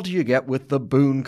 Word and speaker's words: bún: The Vietnamese word for what we know as vermicelli is bún, spelled B-U-N. While I've enjoyed it for years, I'm embarrassed to say I was only recently corrected for pronouncing it bun.